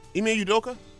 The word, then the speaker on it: Ime Udoka: Please don't let me say this name wrong. Ime Udoka? – I think that's how you say it.